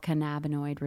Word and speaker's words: cannabinoid: Our bodies have head to toe, these things called cannabinoid receptors. I don't know that if I say that right, but no, you did say it right.